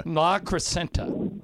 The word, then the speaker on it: La Crescenta: La Crescenta. That's how you say it.